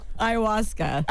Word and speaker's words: Ayahuasca: Ayahuasca. – Ayahuasca.